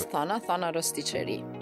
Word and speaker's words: Thana Rosticeri: Yeah, it's Thana Rosticceri.